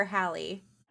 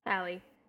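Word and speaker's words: Halle: Um, that said, I really is it pronounced Haley or Hallie? Hallie. Hallie, mm-hmm.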